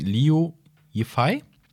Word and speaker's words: Liu Yifei: Die Hauptdarstellerin, jetzt muss ich den Namen richtig aussprechen, Liu Yifei, mhm.